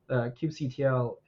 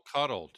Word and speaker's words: kubectl: So when I say kubectl, list out my pods, things like that, or deploy this Nginx service, kubectl, and that's how you pronounce it correctly for those who are listening, is kubectl communicates with the Kubernetes API. – I've heard it called Cuddle. Do you not call it Cuddle? – Kubectl.